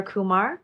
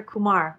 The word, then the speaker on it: Kumar: Because I've grown up saying Claire Kumar, like Harold and Kumar, the movie.